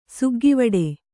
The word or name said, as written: ಸುಗ್ಗಿವಡೆ